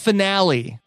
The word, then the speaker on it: finale: Final ride, finally. That was incredible. – It's pronounced finale. – Finale. Oh, sorry. Yes. I've been pronouncing that wrong.